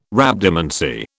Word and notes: Rhabdomancy: British English

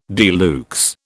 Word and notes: de luxe: British English

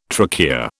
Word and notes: trachea: British English

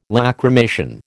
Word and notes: Lacrymation: American English

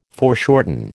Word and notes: foreshorten: American English

